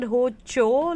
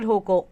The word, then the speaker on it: Hocco: Is it Hocho, like Gucci, because it has two C's in it, H-O-C-C-O, ya fir is it Hoko? Honestly, personally, I like the sound of Hoko because it rhymes with Coco.